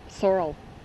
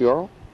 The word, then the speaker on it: sorrel: Sorrel, they call it. And is it pronounced Yorl? – No, sorrel. – S-O-R-R-E-L? – S-O-R-E-L.